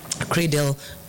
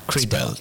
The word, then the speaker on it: CRADL: CRADL pronounced as C-R-A-Y-D-E-L. – CRADL.